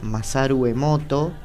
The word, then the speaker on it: Masaru Emoto: También pueden googlear Masaru Emoto. Eh, no tiene ninguna letra doble, es así como, como lo estoy diciendo. Como suena, se escribe.